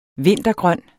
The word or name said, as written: vinca